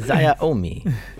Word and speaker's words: Xiaomi: Xiaomi? – How do you pronounce it? – I don't know. – This isn't the pronunciation cast. – Xiaomi. yeah, that's it.